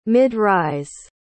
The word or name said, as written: mid-rise